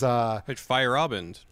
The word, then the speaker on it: Feyerabend: And there's an anarchist sort of philosophy of science guy, I'm going to pronounce his name wrong, but it's like Feyerabend, who just says Feyerabend.